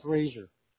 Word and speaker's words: Roeser: Yes, pronounced Razor. – Razor, I apologize, but it is R-O-E-S-E-R? – Yep.